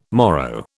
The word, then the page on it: morrow: British English